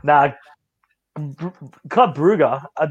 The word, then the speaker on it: Brugge: It is Bruges. – It's Brugge. – It's Brugge. – Well, it is Club Bruges. – His great pronunciations. He butchers the easiest one on the books. – Nah, I'm standing with Club Bruges. – Well, you're sitting and you're wrong.